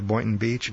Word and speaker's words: Boynton Beach: I hope I'm saying that right. Boynton Beach. – Boynton Beach is right, yeah.